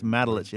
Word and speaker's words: Matulich: Hey, it's Matulich, M-A-T-U-L-I-C-H.